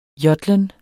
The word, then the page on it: jodlen: [ ˈjʌðlən ]; [ ˈjʌdlən ]